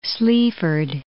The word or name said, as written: Sleaford